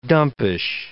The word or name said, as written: dumpish